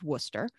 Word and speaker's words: Worcester: It's about 15 minutes west of Worcester, which is not pronounced anything like it looks like on paper.